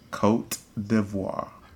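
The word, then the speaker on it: Côte d'Ivoire: Cote d'Ivoire. – Cote d'Ivoire.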